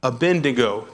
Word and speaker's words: Abednego: And this is the one my wife will tell you I mispronounce every time. Abednego. Did I do it right, Jenny? Oh, good.